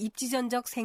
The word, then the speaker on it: 입지전적: 앞서 여러 번 들으셨듯이 입지전적에서 마지막 음절을 적으로 발음하지 않고 적으로 발음했습니다. 그것은 적이라는 전미사 앞에 니은 받침을 쓰는 표현인 입지전이 왔기 때문에 된 소리로 발음하지 않는 것입니다.